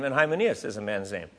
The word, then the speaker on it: Hymenaeus: Hymenaeus. – For some people have deliberately violated their consciences. As a result, their faith has been shipwrecked. Hymenaeus and Alexander are two examples of this.